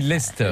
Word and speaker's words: Leicester: Alors on dit pas Leicester. – On dit Leicester. – Leicester. – Le C ne se prononce pas.